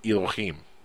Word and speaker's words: Elohim: By the way, Nancy gave me props for my pronunciation of the word Elohim.